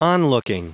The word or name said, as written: onlooking